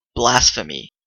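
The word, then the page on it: blasphemy: Canadian English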